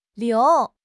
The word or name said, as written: liu2